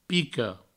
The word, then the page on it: pica: Provençal; pico